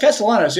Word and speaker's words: Castellanos: But Alex Wood as a left, and this is with the caveat that the Cubs just added Nick Castellanos or whatever his name is. I feel like I'm always butchering that when I say that. Is that right? – It's Castellanos, yeah.